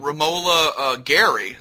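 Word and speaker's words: Romola Garai: And Romola Gary, is that how you pronounce her name? – That's right.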